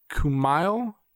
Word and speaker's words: Kumail: His name is Camille Nanjiani. Oh, wow. I butchered his name. – Don't even. – Incredible actor. Kumail Nanjiani. Whatever.